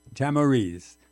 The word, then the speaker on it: Tamariz: His name is Juan Tamariz. Have I got that right? – Tamariz. – So I managed to mispronounce right off the bat. Tamariz. – Most people do.